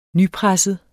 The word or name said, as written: nypresset